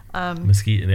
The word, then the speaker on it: Mesquite: There's the Mesquite Dunes, and then there's the Eureka Dunes, if I pronounce that correctly, the first one. – Mesquite, yeah, that's correct.